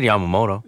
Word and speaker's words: Yamamoto: I think even with the injury to Musgrove, I still think the rotation, considering the way Yamamoto pitched. What'd I say? I knew I said it wrong the second I said it. What's his name? Did I say it? – You said Yamamoto.